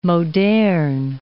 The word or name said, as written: moderne